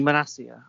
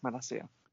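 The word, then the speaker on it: Malacia: But then other unexpected heroes, like the new kid at left back, is it Malakia, however you say his name? – I think Manassia. – Manassia.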